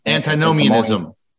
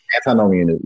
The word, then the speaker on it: antinomianism: How do you say that other thing? – Antinomianism. – Antinomianism. Yeah. Yeah.